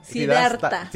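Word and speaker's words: Siddhartha: Sidastra. – Sidarta. – Sidarta.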